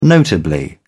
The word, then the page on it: notably: British English